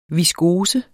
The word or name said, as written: viskose